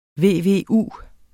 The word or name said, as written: VVU